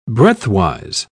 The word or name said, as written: breadthwise